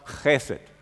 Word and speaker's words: Chesed: In der hebräischen Sprache haben wir hier, und im Psalm 136 zum Beispiel, diese 24 Mal immer dieses eine Wort Chesed. Das ist ein CH, das man ganz hinten im Rachen ausspricht.